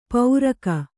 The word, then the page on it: ಪೌರಕ: pauraka